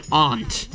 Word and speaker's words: aunt: I thought you were talking about an insect ant. – No. Like the way you said it. – An aunt.